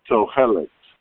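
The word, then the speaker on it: Tohelet (תועלת): The laws of Tohelet. – How do you spell that? – In English, it's T-O-E-L-E-S or E-T in the Sephardic pronunciation.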